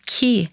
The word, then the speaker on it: ki: But this word, this sound, ki, is of course also the word for who, right? In Spanish and in French. Yeah. Yeah. It turns out that of course it's an alternate pronunciation for chi, for life force, for life energy.